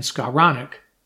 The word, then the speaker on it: Skowronek: and even Ben Skowronek.